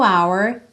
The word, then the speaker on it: hour: Bacana você reparar que esse H do hour é mudo, porque a grande maioria das letras H em inglês tem o som como a gente ouve em hi, hi, hello. Mas o H de hour é mudo.